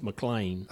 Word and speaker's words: McLean: It's McLean.